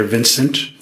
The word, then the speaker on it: Vincent: I'm Dr. Vincent Tranquita. My name is spelled V-I-N-C-E-N-T. My last name is spelled T-R-A-N-C-H-I-D-A.